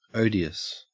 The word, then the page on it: odious: Australian English